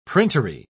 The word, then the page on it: printery: British English